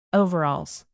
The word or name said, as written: overalls